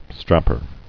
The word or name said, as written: Strapper